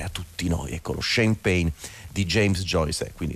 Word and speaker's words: shame pain: Lui lo chiama shame pain, si pronuncia come lo champagne,